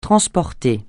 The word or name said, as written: transporter